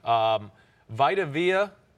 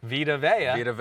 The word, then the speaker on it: Vita Vea: Vita Vea, I always say his name wrong. Vita Vea. – Vita Vea.